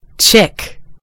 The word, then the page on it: chick: American English